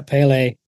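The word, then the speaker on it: Pelé: Yeah, Pele.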